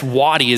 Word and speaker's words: Wuoti: So, I mean, who would have guessed Wadi is how you would get, you know, W-U-O-T-I, right?